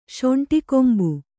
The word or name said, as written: ಶೊಂಟಿಕೊಂಬು